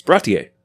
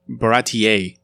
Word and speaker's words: Baratie: It's Baratie.